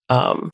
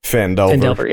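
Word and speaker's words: Phandelver: Fandelver. – Fandelver, yeah, thank you.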